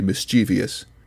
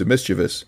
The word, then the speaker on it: mischievous: You see, the word is spelled E-T space C-E-T-E-R-A. It also makes me think Bill is definitely the kind of guy who would say mischievous as opposed to mischievous, which is how the word is spelled and supposed to be pronounced.